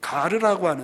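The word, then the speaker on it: 가르라고 (γάρ): 이 헬라어 성경을 이렇게 보시면 가르라고 하는 헬라 말로 이제 발음으로 가르라고 하는 접속사가 계속해서 16절, 17절, 18절, 23절에 이르기까지 이어지고 있습니다.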